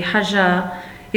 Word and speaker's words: Hajar: Her name, pronounced in Arabic with a soft G, Hajar, is one of the centres of the Hajj, and her quest for water is remembered in the rituals of the Hajj.